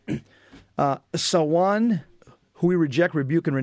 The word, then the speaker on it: Samhain: Yeah, I think it's pronounced it's Sawan.